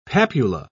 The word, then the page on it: papula: British English